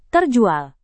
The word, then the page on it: terjual: indonesian